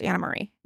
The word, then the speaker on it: Anne Marie: It's a Dutch pronunciation, but it's spelled Anne Marie. It's pronounced Anna Marie.